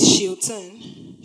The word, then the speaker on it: Shilton: And then we learn that power is Shilton.